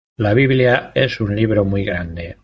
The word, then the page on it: biblia: spanisch